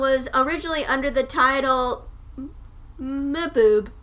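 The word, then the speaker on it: Mbube: It's M-B-U-B-E. – Mboobe. Mboobe. Because it's African. And I think that's how you pronounce stuff like that. When like the M and the B are right next to each other.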